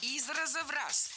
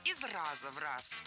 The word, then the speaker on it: из раза в раз: Из раза в раз. Здесь в этом выражении ударение поставили на предлог. Но это неверно. Должно быть из раза в раз. Предлог оставляем безударным.